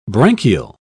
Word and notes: branchial: British English

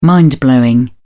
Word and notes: mind-blowing: British English

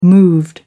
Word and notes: moved: American English

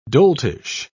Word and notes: doltish: British English

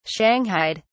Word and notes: shanghaied: British English

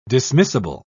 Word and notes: dismissible: British English

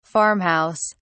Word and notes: farm house: British English

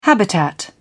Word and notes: habitat: British English

